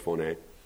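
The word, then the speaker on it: phoné (φωνή): It looks like phone, P-H-O-N-E, pronounced phoné.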